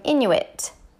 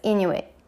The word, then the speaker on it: Inuit: Now, I would have pronounced them Inuit because that's how I thought it was pronounced. But I learned that you don't actually pronounce the T loudly, so it's Inuit.